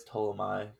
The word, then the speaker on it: Ptolemy: I believe that's Ptolemy. I think the P is silent.